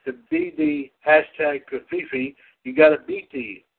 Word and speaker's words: covfefe: The new word Kafifi, that's the best way I can pronounce it, C-O-V-F-E-F-E, has been